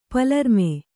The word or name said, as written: ಪಲರ್ಮೆ